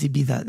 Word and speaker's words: Ibiza: He says Ibiza. That's how they say it locally.